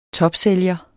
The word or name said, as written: topsælger